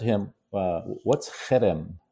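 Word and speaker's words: herem: Cherem. So it's H-E-R-E-M, but it's like a hard guttural. Ha. – Chayrim.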